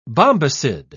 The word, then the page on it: bombycid: British English